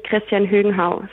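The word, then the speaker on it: Christian Høgenhaug: Ähm, es gibt natürlich auch regionale Unterschiede, auch in einem kleinen Land wie Dänemark, aber ähm, die meisten würden tatsächlich Christian Högenhau sagen. Also das G schon mit aussprechen, ähm, aber nicht zu sehr betonen.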